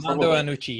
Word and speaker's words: Armando Iannucci: Am I pronouncing that wrong? – Armando Iannucci, yes.